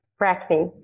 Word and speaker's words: Brackney: Brackney, yes.